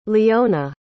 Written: liona

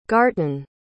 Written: garton

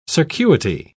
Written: circuity

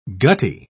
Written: gutty

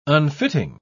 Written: unfitting

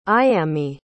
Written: ayami

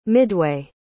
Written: midway

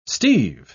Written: steeve

